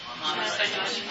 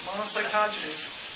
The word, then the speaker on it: monocytogenes: Monocytogenes. – Okay. It's not monocytogenes. Monocytogenes.